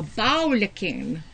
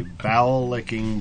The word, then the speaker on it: bowlicken: And it's really a bowel licking. I don't know if that's how they pronounce it. – A bowel licking. – B-O-W-L-I-C-K-E-N. – Oh, okay. B-O-W.